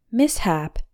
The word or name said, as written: mishap